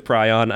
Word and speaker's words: prion: Real quick, there's a little bit of a discrepancy on how that word is pronounced. P-R-I-O-N, sometimes referred to as a prion. – But sometimes pronounced maze. – God damn it. But I looked it up. Prion apparently was the original version of that. However, it is sometimes pronounced now. And also, it fits with the English language because if you look at like stuff that is on that range, like lion, you wouldn't call it a Leon.